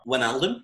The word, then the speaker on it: Wijnaldum: Wijnaldum, yeah.